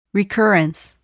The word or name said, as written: recurrence